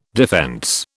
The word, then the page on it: defence: British English